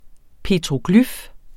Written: petroglyf

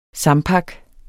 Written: sampak

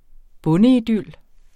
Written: bondeidyl

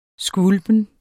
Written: skvulpen

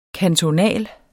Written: kantonal

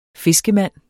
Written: fiskemand

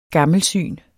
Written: gammelsyn

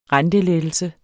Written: rentelettelse

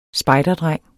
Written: spejderdreng

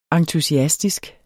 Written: entusiastisk

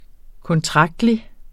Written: kontraktlig

